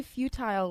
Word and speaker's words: futile: Also, why did you say futile, like a feudal system? – Feudal.